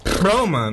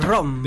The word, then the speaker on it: Roma: You can't roll your R's? – No. I'm going to try that again. – Please try it one more time. – Roma, no.